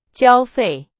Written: 交费

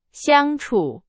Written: 相处

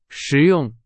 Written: 实用

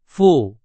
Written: 富

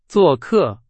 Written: 做客

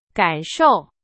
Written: 感受

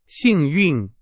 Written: 幸运

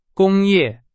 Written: 工业

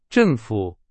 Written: 政府

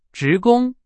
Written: 职工

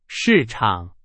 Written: 市场

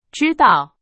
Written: 指导

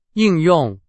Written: 应用

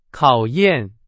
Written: 考验

